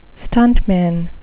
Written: stuntman

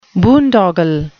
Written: boondoggle

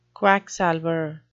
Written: quacksalver

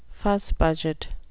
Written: fussbudget

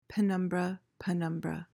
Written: penumbra

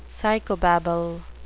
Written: psychobabble

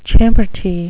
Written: champerty

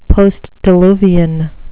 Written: postdiluvian

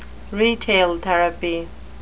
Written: retail therapy

